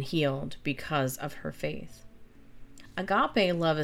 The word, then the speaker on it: agape: So the Greek word agape, pronounced, of course, agape, spelled A-G-A-P-E, and variations of it are frequently found throughout the New Testament, but rarely in non-Christian Greek literature.